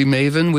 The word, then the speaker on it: maven: I'll be Maven, you know, and may this station be Maven, which means information resource. It rhymes with Raven.